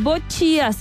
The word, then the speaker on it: boccia: No, no, las bochías, se escribe B-O-C-C-I-A. – Ah, bochía.